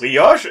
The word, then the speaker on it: Brioche: Aber wie spricht man das aus? – Brioche immer noch. – Warum? Das ist Französisch. – Man schreibt, ich habe extra B R I O C H E. Brioche, ja.